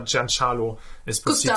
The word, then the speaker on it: Giancarlo Esposito: Meine, er hat mal auf Twitter hat er seinen Namen ausgesprochen, irgendwie in einem Video, und hat da Giancarlo Esposito.